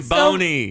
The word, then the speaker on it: Zamboni: Yeah, Zambinis. Right? – I thought it was Zambino. – We've said Zamboni.